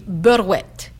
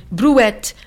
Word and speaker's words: brouette: Par exemple, moi, je dis « berouette ». Berouette, et pas... Je me souviens même pas plus comment ça dit en français, vraiment. Brouette, un outil pour...